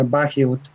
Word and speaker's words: Bagehot: Se pronuncia Bagehot, y que lo que él escribe, ¿verdad?